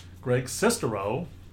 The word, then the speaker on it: Greg Sestero: Greg Sestero, we have been informed that that's the proper pronunciation, or I think it's the proper pronunciation of his last name.